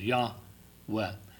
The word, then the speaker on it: Yahweh: You see the capital O-R-D is the King James way of translating Jehovah or Yahweh, as we believe the pronunciation in Hebrew would have been. Two syllables, Yahweh.